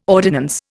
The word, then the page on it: ordinance: Indian